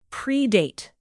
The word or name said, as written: predate